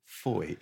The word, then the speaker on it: Fowey: It's FOI to rhyme with joy.